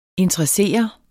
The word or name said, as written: interessere